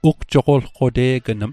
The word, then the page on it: ugjoqolqote'gnm: Mi'gmaq